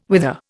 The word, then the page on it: wither: Indian